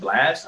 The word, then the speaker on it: BLXST: one artist that I've been listening to that I just found out about, Blast. I think that's how you say his name. BLXST. – Yeah, I would say so.